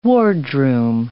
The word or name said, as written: wardroom